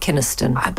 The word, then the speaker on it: Kynaston: We've been talking about Every Man for Himself by Beryl Bainbridge, Free Love by Tessa Hadley, and I'm sorry, I mispronounced his surname. You say David Kynaston. – But now I'm doubting myself. You're probably right. – Well, let's do it twice. David Kynaston.